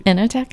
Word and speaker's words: Enoteca: Sofia's Cucina y Enoteca. Did I say that right? – Enoteca.